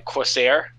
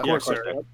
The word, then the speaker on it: Corsair: Corsair? – Yeah. – Corsair.